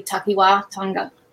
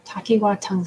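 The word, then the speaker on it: takiwātanga: I'm going to mangle it, so I don't want to say it, but the line above the A is a long A, so it would be Takiwa Tanga. – Okay. Takiwa Tanga.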